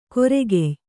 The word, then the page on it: ಕೊರೆಗೆಯ್: koregey